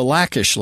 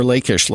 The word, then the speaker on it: Lachish: The next great find that I'd like to mention here real quickly is the Lachish letters, or Lachish letters, depending on how you like to pronounce it.